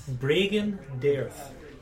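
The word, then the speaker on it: Bregan D'aerthe: Bragandirth? – Bragandirth.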